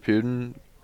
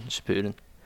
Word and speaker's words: Shippuden: I haven't watched a lot of Shippuden, which I don't think I pronounced that right, and I'm sorry. – No, you did, Shippuden.